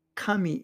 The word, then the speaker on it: CAMI: The word CAMI is pronounced Kami in Japanese, and it means God or deity, among other various translations.